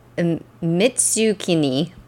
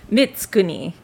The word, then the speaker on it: Mitsukuni: Mitsukuni. – Close. Mitsukuni.